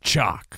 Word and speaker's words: CHOQ: C-H-O-Q. It's pronounced chalk.